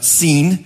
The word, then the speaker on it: Sin: Now, don't mistake this for the word sin that we use for breaking God's commands. It's a name in Hebrew. It's pronounced Sin.